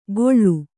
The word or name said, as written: ಗೊಳ್ಳು